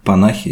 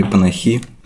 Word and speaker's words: Панахи: Ну, хорошо, что мы сегодня обсуждаем панахи или панахи. – Сейчас да. – перестроиться. – У Наверное, меня я все время информацию. сбиваюсь на. Ну, ударение, по идее, на последний слог должно быть.